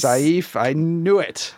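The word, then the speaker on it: Saiph: Well, so back when I started Stargazers, the show, I always pronounce it Saif, and they corrected me and said, it's safe. You pronounce it safe.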